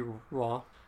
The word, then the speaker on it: Roy: It's R-O-Y, but I never pronounce it correctly. – R-O-Y? – Yes. – That would be Roy. – But every time I say that for someone, you say, no, it's Roy.